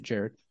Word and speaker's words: Gerard: It's spelled Gerard, but I guess you pronounce it Jared.